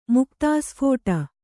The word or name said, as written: ಮುಕ್ತಾಸ್ಫೋಟ